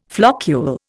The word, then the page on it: floccule: Indian